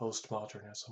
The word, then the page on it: postmodernism: American English